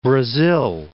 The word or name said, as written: Brasil